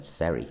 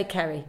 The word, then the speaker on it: Ceri: So it's, but it's spelled C-E-R-I. And it almost went Seri. – I'd say Kerry.